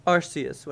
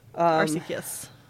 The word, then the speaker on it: Arceus: Okay, I was gonna ask if we landed on a pronunciation yet. – No, it is, it's both. – Okay. Arceus. – I like Arceus. I think that's how I started saying it, but Arceus made more sense for some reason.